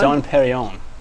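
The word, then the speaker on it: Dom Pérignon: But you get but when you break it down, it was like 7 or 8 bottles of Dom Pignon. – Dom Pignon. – Is that how you say it, Seuss? Dom Pignon. – Dom Perignon. – What? – The champagne Dom Perignon.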